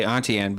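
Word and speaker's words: Auntie Anne's: I've never heard it said like that before. – How do you say it? I always say Auntie Ann, but I it's just a dialect thing.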